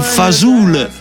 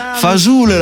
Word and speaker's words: fasul: Però pasta e fasul, fasul l'ha proprio pronunciato bene, perché giustamente, come mi sottolinea Angelo, loro l'italiano lo parlavano, ma lo parlavano dialettale, quindi dialetto del sud.